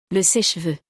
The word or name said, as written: Le seche-cheveux